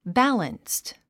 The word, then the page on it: balanced: bǽlənst; バァランスト